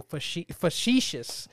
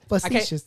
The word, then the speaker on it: facetious: You had me being facetious. – Facetious. – Facetious. I got to say it correctly if I'm going to use that big-ass word.